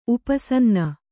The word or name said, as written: ಉಪಸನ್ನ